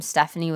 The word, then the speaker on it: Stefanie: Say a lot of people spell my name Stephanie with a P-H instead of an F. So they spell it S-T-E-P-H-A-N-I-E instead of S-T-E-F-A-N-I-E.